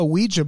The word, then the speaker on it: Ouija: He picks up a Ouija board and he pronounces that word O-U-E-J-A, basically. Like Ouija. – He sounds out every letter and it's very strange.